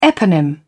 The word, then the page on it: eponym: British English